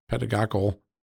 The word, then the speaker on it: pedagogical: And they claimed in the filings that they had a pedagogical, if I said that right, pedagogical interest. Yes, thank you.